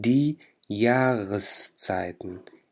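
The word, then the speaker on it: die Jahreszeiten: هون لما وصلت بكلمة تانية بدي ألفظ حرف الآ، فبقول دي ياغسزايتن، دي ياغسزايتن، وحرف الآ هون تلفظ مثل حرف الغين باللغة العربية.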